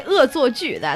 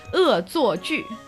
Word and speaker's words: 恶作剧: So we say 恶作剧, that's how you say it in Chinese. – 恶 what? – 恶作剧.